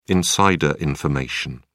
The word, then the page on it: insider information: British English